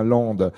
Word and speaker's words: Lempdes: Lande, qui s'écrit Lambdes, L-E-M-P-D-E-S, mais on dit bien Lande.